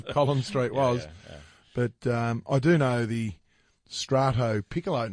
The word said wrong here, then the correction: ristretto: I like it because it's, is that how we pronounce it? – Ristretto, there you go.